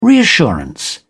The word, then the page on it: reassurance: British English